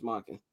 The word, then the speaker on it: Monken: It's Monken.